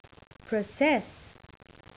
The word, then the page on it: process: verb